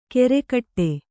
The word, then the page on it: ಕೆರೆಕಟ್ಟೆ: kere kaṭṭe